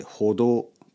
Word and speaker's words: 歩道: 歩く道と書いて歩道と読みますね。